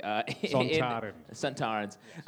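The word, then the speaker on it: Sontarans: There's been other Santorans, am I saying that correctly? – Santarans.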